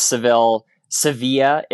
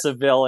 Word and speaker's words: Seville: Yes, it's Sevilla, if you were saying it as the locals say, but it's Seville in English.